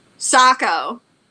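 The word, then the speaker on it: Saco: Do you remember what it's actually pronounced as? – Socko. – Socko, yes. – Like a sock, but it doesn't say sock.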